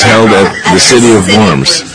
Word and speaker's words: Worms: Deed of worms? How do you pronounce that word? – Diet of worms. – What is diet? – Oh, worms. The W is pronouncing a V.